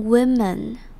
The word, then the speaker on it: women: 那接着我们来看 women 这个字，women 它是 woman 的复数，那变成复数之后，这边的 o 就要念成短母音 e 的声音，women。